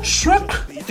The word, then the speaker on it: Shrek: Shrek.